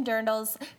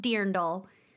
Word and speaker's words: dirndl: Erica is rolling in her non-existent grave right now because it's pronounced dirndl. – Okay. – So we got to say dirndl. – And it's spelled D-I-R-N-D-L. – It's spelled dirndl. – Because German is crazy.